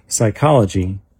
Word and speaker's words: psychology: In the word psychology, the P is silent. In the word tsunami, the T is silent.